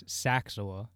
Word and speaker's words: Saczawa: It's Saczawa. – Saczawa. Wow. I'm a fucking idiot. – It's said exactly how it's spelled.